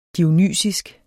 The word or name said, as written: dionysisk